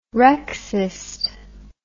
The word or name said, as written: rexist